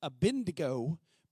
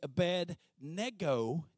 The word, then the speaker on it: Abednego: So many times we pronounce that Abednego, but it's actually Abednego. Nego was a Babylonian god, Nego, and that's the slave or servant of Nego, Abednego.